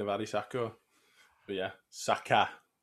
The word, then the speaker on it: Saka: Saka. Yeah, it's Saka.